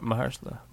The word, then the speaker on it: Mahershala: Marshalla? How you say his name? – Ali. Marshalla. – Marshalla Ali? That's right. – Mahershala. – Mahershala. You made me say it like you. Mahershala.